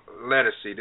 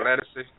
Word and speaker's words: Ledisi: Now, the song was recently brought back into prominence by a singer named Ledisi. Did I pronounce that right? – Yeah. – Ledisi? Okay.